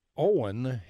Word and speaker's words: Eoin: Owen, his first name is spelled E-O-I-N. – Mm-hmm. – And, of course, I had to look that up. That's the Irish way.